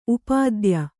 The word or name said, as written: ಉಪಾಧ್ಯ